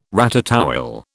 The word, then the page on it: ratatouille: British English